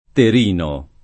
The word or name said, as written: Terino